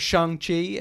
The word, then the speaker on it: Shang-Chi: Or Shang-Chi, as it's supposed to be pronounced.